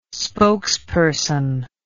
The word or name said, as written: spokesperson